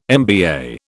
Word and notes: MBA: British English

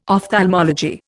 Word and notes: ophthalmology: Indian

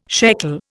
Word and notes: shekel: Indian

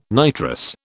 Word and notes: nitrous: British English

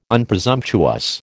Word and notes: Unpresumptuous: American English

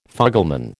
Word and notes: fugleman: American English

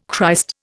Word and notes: Christ: Indian

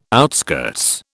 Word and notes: outskirts: British English

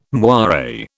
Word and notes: moire: British English

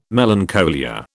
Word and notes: melancholia: British English

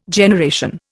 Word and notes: generation: Indian

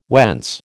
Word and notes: whence: American English

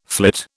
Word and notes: flit: British English

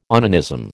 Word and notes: onanism: American English

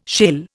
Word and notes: shale: Indian

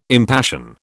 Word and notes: impassion: British English

